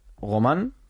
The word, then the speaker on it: Romain: Romain. De Romain, igual que Bardet. – Qué bien pronuncia, ¿no? – Y Grégory.